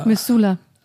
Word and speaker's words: Missoula: Wie spricht man das aus? Missoula. – Missoula.